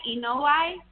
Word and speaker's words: Inoue: We got to see who this guy Inoue is. Am I pronouncing that right? Inoue? – Inoue, yes.